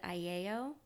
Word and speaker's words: Aiello: And when my grandparents came over here from Sicily, sometimes people would pronounce it Aiello before that, but it's pronounced Aiello here in the United States.